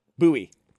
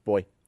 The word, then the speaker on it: buoy: B-U-O-Y. – What? Booy. – Mm. Yeah, I got yelled at because I don't pronounce it that way. – How do you pronounce it? – Boy. – Boy? – Boy. – Yeah, that's weird. And someone was like, it's pronounced booy. And I was like, I mean, yeah, in some places, but not everywhere.